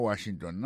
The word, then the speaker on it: Washington: Si nous mettons accent sur accent encore, on voit que le mot Washington, l'accent tombé sur le premier syllabe.